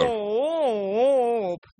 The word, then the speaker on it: ЦООП: Аббревиатура именно так и читается. – Пропойте. Центр. – ЦООП. – Да. Что-то вы в нос поете, как будто это не это самое. Французский центр, понимаете. Нет, это русский. ЦООП. Вот так надо.